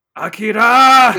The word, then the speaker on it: Akira: So for us, I think the obvious one that we gravitated towards was Akira.